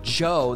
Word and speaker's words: Djo: He puts music out under the name Joe. That's spelled D-J-O.